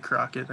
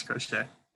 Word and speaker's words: Crochet: Garrett Crochet. – What's his name? Fuck. – Garrett Crochet. – It's actually Crockett. No, it's Crochet. – You mean Crotchet? – All right.